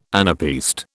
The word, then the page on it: anapaest: British English